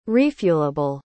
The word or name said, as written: refuelable